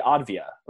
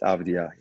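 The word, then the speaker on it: Avdija: It's Denny Advia, right? – Yeah, it's Denny, which usually it's not a common name in Israel. Usually Israelis call it Danny, Danny is very common. But Denny, it's not so common at all. But it's Denny and it's Advia. Yeah. – Advia. Yeah. Okay, Avdia. Yeah, people in America butcher that one quite often.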